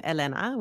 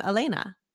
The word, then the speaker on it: Elena: And, you know, depending on what room I'm in, some people will say my name Elena, which I love, uh-huh, and some people will say my name Elena.